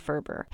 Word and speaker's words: Ferber: I was on my way to Chapters and I just kept saying rhymes with Gerber, Ferber, because I didn't want to forget who the author was that I was looking for when I got there. I wasn't really sure how many other books maybe were called How to Solve Child's Sleep Problems, so I wanted to make sure I got the right one. And it was really easy, because Gerber rhymes with Ferber, and Gerber is such a popular baby food company that it really kind of just tied together.